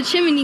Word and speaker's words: chimney: She thought of the chimney going down the chimney. – Chimney. There's no extra syllable.